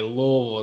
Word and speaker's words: Louisville: And you have to say it that way, Louisville. It's kind of one syllable.